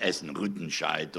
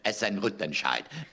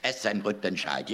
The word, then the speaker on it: Essen-Rüttenscheid: Da muss man nämlich die Silben immer gleich lang halten. Ganz wichtig. Es heißt nicht Essen-Rüttenscheid oder sowas. Kannst du nicht. Rütten. Essen-Rüttenscheid. Essen-Rüttenscheid. Jeder Vokal wird ausgesprochen.